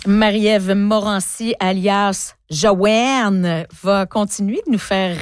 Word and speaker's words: Jowanne: Ça aussi, ça vous rend heureuse hein, de dire Joanne. – Joanne, il faut dire que ça s'écrit J-O-W-A-N-N-E.